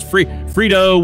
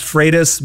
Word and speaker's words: Freitas: What do I say, dude, Nick? What's the right way to pronounce it? – Technically, it's pronounced Freitas, but my drill sergeant taught me to respond to anything close. – all right. Freitas is the right way to say it.